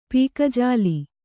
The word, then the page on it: ಪಕ್ಕಜಲ್ಲಿ: pakka jalli